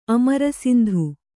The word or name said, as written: ಅಮರಸಿಂಧು